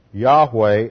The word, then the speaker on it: YHWH: The Hebrew is the sacred tetragrammaton, YHWH, which is often pronounced Yahweh and is the personal name of God.